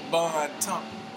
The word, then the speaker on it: Bon Temps: It's Bon Temps. Golly, I've been coming here all this time and still can't pronounce it. Bon Temps. It's spelled B-O-N-T-E-M-P-S.